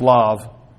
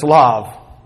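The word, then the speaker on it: lav (לב): But there's another term for the heart being used here, and it is just lav, or is pronounced lav. It's written for the transliteration, it would be L-E-B. But it's lav, right here.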